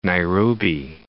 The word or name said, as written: Nairobi